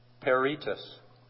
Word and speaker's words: peritus: Peritus. – How do you spell it? – P-E-R-I-T-U-S. – Oh, it's pronounced peritus, pardon me.